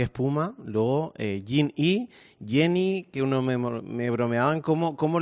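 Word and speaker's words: Gen-E: Bueno, el nombre en inglés correctamente se pronunciaría Jenny, uh-huh. Pero bueno, lo podemos decir como quieras, Gené, porque es de generación eléctrica, vamos, es igual, nos pasa igual con el Mach-E.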